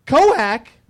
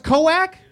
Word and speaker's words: Koek: It's K-O-E-K? – Coac. – K-O-E-K is pronounced Coac?